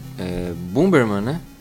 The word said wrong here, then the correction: Bomberman: Eu falei errado é aí. É Bomberman. É Bomberman, é Bomberman.